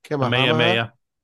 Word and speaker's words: Kamehameha: It opened in March of 1956 on the site of a former royal tarot patch belonging to, I'm going to butcher this, King Kamehameha the Third. – Kamehameha. – Okay, thank you.